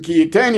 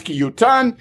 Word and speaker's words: ki yutan: It's as if it's written ki yitain, even though it's pronounced ki yutan.